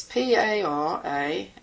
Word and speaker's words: Paramaeth: It's Paramaeth, and the stress is on the second syllable.